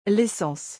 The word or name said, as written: l'essence